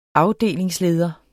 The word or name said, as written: AFDELINGSLEDER